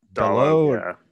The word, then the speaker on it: Dalot: Dalot, yeah.